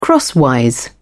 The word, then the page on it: crosswise: British English